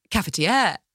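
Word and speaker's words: cafetière: Because I always say it wrong. Cafetiere. – Yes, yeah, that was perfect.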